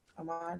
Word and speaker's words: Ahmaud: So there's one for Breonna Taylor, George Floyd, Ahmaud Arbery. I'm saying that right? Ahmaud? – Yeah.